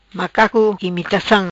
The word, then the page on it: Macaco Imitacam: patua